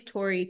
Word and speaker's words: Tori: So my name's Tori Terhune, and it is spelled TORITERHUN as in Nancy E.